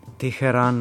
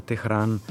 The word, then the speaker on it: Teheran: Tokio z jem pisano namesto z ypsilonom, recimo Seul namesto Seul, Teheran namesto Tehran, Sana namesto Sana'a in tako dalje.